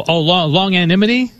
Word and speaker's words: longanimity: Oh, longanimity? – Longanimity, okay.